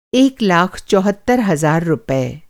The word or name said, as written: चौहत्तर